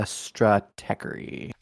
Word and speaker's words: Stratechery: So last week's issue of AMZ Weekly, um, have you ever read the blog Strategery? – It's uh, Stratechery. – That it is. I always say it wrong.